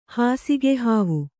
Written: ಹಾಸಿಗೆಹಾವು

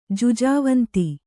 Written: ಜುಜಾವಂತಿ

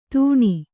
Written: ತಣ್

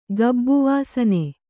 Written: ಗಬ್ಬುವಾಸನೆ

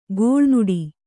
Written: ಗೋಳ್ನುಡಿ

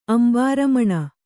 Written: ಅಂಬರಮಣಿ

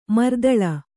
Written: ಮರ್ದಲ

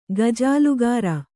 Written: ಗಜಾಲುಗಾರ

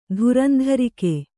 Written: ಧುರಂಧರಿಕೆ

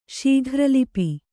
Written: ಶೀಘ್ರಲಿಪಿ

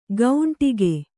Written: ಗೌಂಟಿಗೆ

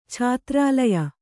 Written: ಛಾತ್ರಾಲಯ

